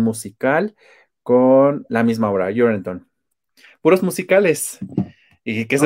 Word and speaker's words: Urinetown: la parte complicada con Orineton, así se pronuncia. – Urinetown. – Urinetown, ok.